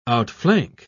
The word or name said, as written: outflank